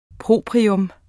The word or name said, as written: PROPRIETS